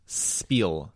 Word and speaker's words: spiel: Phonetically, it is pronounced spiel. – Yeah, because spiel. No H.